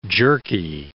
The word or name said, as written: jerky